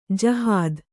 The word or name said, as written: ಜಹಾದ್